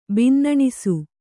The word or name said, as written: ಬಿನ್ನಣಿಸು